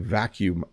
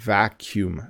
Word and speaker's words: vacuum: And I will say this too, it's a tricky word to pronounce because of when you see it, but it actually isn't too bad. Vacuum, vacuum, to vacuum.